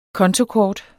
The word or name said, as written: kontokort